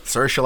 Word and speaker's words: Saoirse: I'll move on to Saoirse Ronan. – Saoirse like inertia.